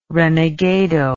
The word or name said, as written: renegado